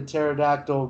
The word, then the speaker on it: pterodactyl: uh, how come you couldn't hear the pterodactyl go to the bathroom? – I have no idea. I never heard this joke. Go on. Because the P is silent.